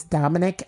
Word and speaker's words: Dominick: My at name is Dominic Evans. That's spelled D-O-M-I-N-I-C-K. And then Evans is just Evans, but I'll spell it just in case. E-V-A-N-S.